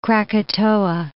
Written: Krakatoa

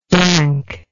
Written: dankness

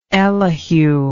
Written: elihu

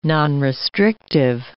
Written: nonrestrictive

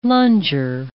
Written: lunger

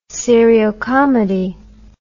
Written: seriocomedy

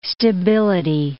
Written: stability

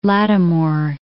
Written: Lattimore

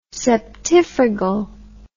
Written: septifragal

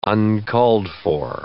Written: uncalled-for

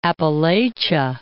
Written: Appalachia

